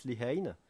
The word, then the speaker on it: Lehane: Alors son nom n'est pas facile à prononcer en français, donc je dis Dennis Lehane. Alors ça s'écrit Denis, donc comme le prénom Denis, mais avec deux N. Et Lehane, ça s'écrit L-E-H-A-N-E.